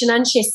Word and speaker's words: Seanchaís: Oh, it's an Irish, I'm maybe not pronouncing it right, it's Seanchaís.